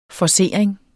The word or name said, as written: forcering